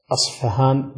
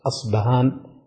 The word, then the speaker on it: أصفهان: فقد ينطقها بعض الناس أصفهان بالفاء، وقد ينطقها بعض الناس أصبهان بالباء. ولهذا لو قيل العقيدة الأصبهانية أو العقيدة الأصفهانية كلها سليمة وصحيحة.